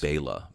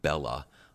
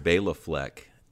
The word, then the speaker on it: Bela Fleck: And it is Bela, by the way. It is not Bella. Dave, I don't know why you've been mispronouncing it, but we've been guilty, thanks to Dave, of mispronouncing it as well.